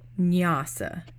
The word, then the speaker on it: Nyasa: So that's something called Nyasa, and that's spelled N-Y-A-S-A, kind of like the final portion of vinyasa, but it's a different word.